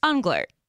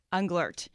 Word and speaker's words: Unglert: Unglert. – Unglert, yeah. – There you go. – A lot of people don't pronounce the T at the end, Unglert. – Unglert.